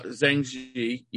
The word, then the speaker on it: Zhang He: I'm sure I butchered the name. – No, Zhang He. Yeah. I mean, like, you got it right, basically.